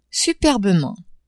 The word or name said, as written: superbement